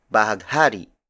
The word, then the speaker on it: bahaghari: And the Tagalog word for rainbow is bahaghari.